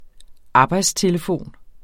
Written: arbejdstelefon

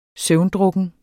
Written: søvndrukken

